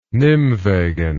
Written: Nimwegen

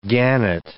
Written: gannet

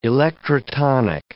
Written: electrotonic

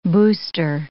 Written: booster

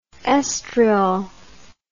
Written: oestriol

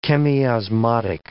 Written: chemiosmotic

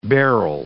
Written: beryl

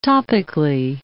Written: topically

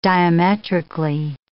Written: diametrically

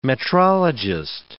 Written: metrologist

